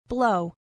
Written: blow

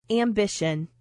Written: ambition

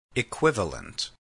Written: equivalent

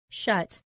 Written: shut

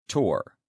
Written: tour

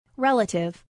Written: relative

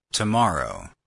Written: tomorrow

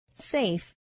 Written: safe

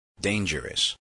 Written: dangerous

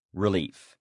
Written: relief

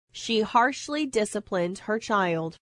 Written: discipline

